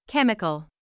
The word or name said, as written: chemical